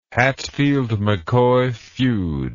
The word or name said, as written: Hatfield McCoy feud